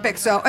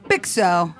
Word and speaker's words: Bixo: Yeah, just Bixo. It's like BXO, but like Bixo.